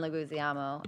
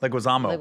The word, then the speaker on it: Leguizamo: Actually, I really like John Leguizamo. I always say his last name wrong, but Leguizamo.